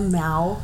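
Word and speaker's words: Mau: Back to our Catwoman hashback, but 3 Egyptian Miu or Mayu? Or Miu, I think that's how you pronounce it. – The cat? – Yeah. – It's an Egyptian Mao. – Oh, it's called a... – Did you not... – It's called a Mao.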